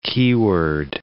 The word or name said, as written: keyword